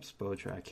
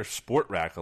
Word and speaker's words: Spotrac: I've heard Spotrac. I hear Sportrac a lot, which is wrong because there's no R before the T.